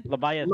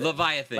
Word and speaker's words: Leviathan: It's the how do you pronounce this? Is it Leviathan? Leviathan? – Leviathan. – Leviathan. – Leviathan. – Like yes. – Like the mythical creature.